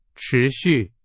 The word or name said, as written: 持续